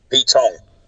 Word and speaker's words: Pete Tong: Pete what? Pete Tom? – Pete Tong. – How do you spell that? – Anyway, it's the name Pete, P-E-T-E. – Yeah. – And then the last word is T-O-N-G.